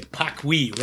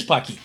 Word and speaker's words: Paqui: I should say Pakui, right? P-A-Q-U-I, that's the way it's pronounced. – Pocky. – Pocky, it is Pocky.